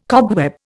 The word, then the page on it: cobweb: Indian